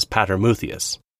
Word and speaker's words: Patermuthius: He was probably from nearby, since he had an Egyptian name, which we Latinize as Patermuthius, much to the confusion of Latin-speaking scholars, because the first two syllables sound like the Latin for father, making it sound like his name is Father Muthius.